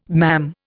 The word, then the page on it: mam: Indian